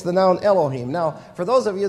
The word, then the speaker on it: Elohim: Elohim. And the him, that is spelled H-I-M in English, but it's pronounced him in Hebrew, the him is a plural ending. So when you read in the beginning God, G-O-D, it's Elohim, there's a plural noun that's used there, so that is true.